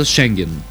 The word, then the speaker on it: Шенген: Одобриха докладите на България за Шенген. Ударението е правилно, не се мислете, че нещо се шегувам.